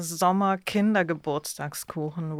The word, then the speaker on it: Sommer Kindergeburtstagskuchen: How do you say it? – It's a Sommer Kindergeburtstagskuchen, which is a name I came up with because the cake has actually no name.